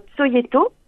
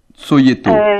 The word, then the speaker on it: Cojeto: Si vous avez accès à SoundCloud, par exemple, vous pouvez passer le titre Tsoyeto. – Tsoyeto. – Ça s'écrit, comme ça tout le monde le saura, oui. – C-O-J-E-T-O.